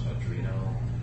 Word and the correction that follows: Arduino: Arduino, whatever that's called.